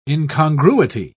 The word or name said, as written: incongruity